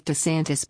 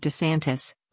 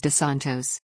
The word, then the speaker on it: DeSantis: This is Newsom's latest targeting of DeSantis, whose name he mispronounced as DeSantos.